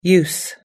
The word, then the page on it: use: American English